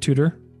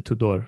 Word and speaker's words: Tudor: In English, you could pronounce it Tudor, like okay. Kind of the English house or the watch. But I mean, in Romanian, I'm Romanian, I'm Eastern European, so we pronounce it Tudor, like a little bit stronger.